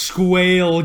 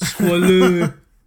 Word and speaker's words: Squale: It's not pronounced squale, guys. Squalu. Squalu. Yeah.